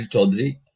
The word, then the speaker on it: Chaudhary: Because I can't roll the R in the way that you do to make it sound right. – My name is Kabir Chaudhary, and yeah, Tommy, thanks for having me on this podcast.